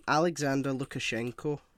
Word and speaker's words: Alexander Lukashenko: Alexander Lukashenko.